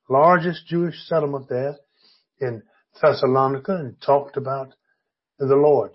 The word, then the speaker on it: Thessalonica: And in our text today, Paul has traveled across into the city of Thessalonica, is the way we usually hear it spoken. Another way is Thessalonica. That's another way of pronouncing it. I usually use Thessalonica.